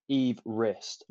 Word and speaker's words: Everest: It's not actually pronounced Everest, or even Everest. Mount Everest is apparently supposed to be pronounced Mount Everest, as in.